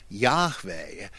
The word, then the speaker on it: Yahweh: The tetragrammaton is the name Yahweh. That's really, I think, how you're supposed to say it.